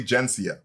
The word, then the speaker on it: Intelligentsia: It's Jensia now. Anyways. Like it's officially Jensia with that soft G.